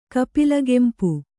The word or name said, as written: ಕಪಿಲಗೆಂಪು